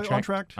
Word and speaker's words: Entr'acte: And then you come back with your, how do you say it? Entr'acte? Is that how you say it? – Oh, yeah. Entr'acte.